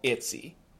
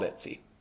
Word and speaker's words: ITZY: I'm so happy you screamed because the first group I want to talk about is a group called ITZY. Doesn't have much to do with screaming, but it's a group called ITZY. – Like ITZY Bitsy? – Yeah, like ITZY Bitsy Spider.